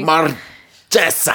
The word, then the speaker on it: Marchesa: Queen Marchesa. – Nope, gotta roll your R. – Let me tell the story. – Marchesa. – Okay, like that.